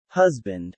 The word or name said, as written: husband